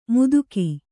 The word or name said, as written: ಮುದುಕಿ